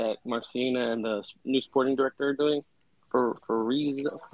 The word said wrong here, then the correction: Ferruzzi: I can never pronounce it. – Ferruzzi.